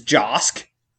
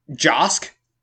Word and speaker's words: Josk: Josk. – Oh, okay. – It rhymes with the normal American human name, Bosk. That's where it came from. – It's like Josh, you know, with a K.